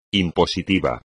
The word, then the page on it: impositivo: espagnol